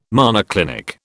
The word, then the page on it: monoclinic: British English